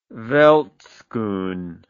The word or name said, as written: veldschoen